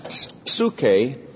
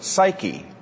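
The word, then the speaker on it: psuche (ψυχή): And the word for soul is the word psuche. And it's pronounced psuche, but if you transliterate it, it would look like this. And you've seen that word. It says, we would pronounce it psyche. But it comes from the Greek word psuche.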